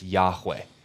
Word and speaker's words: Yahweh: Sometimes rendered Jehovah, that's the German pronunciation, but the closest we can get to in English tongue is Yahweh.